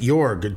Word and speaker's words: Jorg: I wore what I think you pronounce it Jorg, J-O-R-G, Jorg Gray.